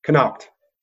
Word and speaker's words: Connacht: This is a Connacht. Probably said that wrong. – That's actually pretty good. – What do you say? – Connacht. – Close.